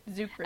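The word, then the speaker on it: Czuchry: And she says, hey, look, there's Matt Chakri? – Zucri. – As Logan's... Huh? – Zucri. – Oh, it's Zucri? The C is silent.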